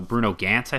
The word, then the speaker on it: Bruno Ganz: Uh, with an absolutely amazing performance from Bruno Bruno Ganz, I think is how you, mm-hmm. is that right? – Is how you say his name? Yep.